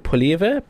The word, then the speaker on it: Poilievre: Yeah, the Conservative Party leader, Pierre, I can't pronounce his name, Poilievre.